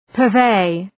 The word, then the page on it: purvey: pɜ:r’veı